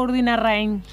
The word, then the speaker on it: Urdinarrain: ¿Urdinarrain o Urdinarrain? – Urdinarrain. – Rein. – Sin acento. Urdinarrain. – Rein. – Bueno. Mira, yo digo Rein. – Está chequeado esto, ¿no? Está confirmado esto. – Sí. Lo dije mal toda la vida. – Sí, los vecinos le dicen Urdinarrain. – Pero yo leo Urdinarrain con A. – Sí. – Y es Urdinarrain con E.